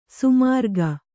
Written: ಸೋಮರಾಗ

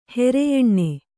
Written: ಹೆರೆಯೆಣ್ಣೆ

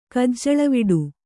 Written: ಕಜ್ಜಳವಿಡು

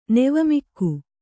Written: ನೆವಮಿಕ್ಕು

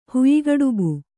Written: ಹುಯ್ಗಡುಬು

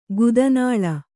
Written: ಗುದನಾಳ

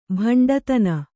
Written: ಬಂಟುತನ

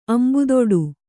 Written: ಅಂಬುದೊಡು

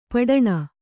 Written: ಪುಟಾಣಿ

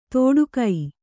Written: ತೋಡುಗೈ

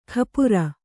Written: ಖಾಪುರ

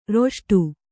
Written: ರಿಷ್ಟ

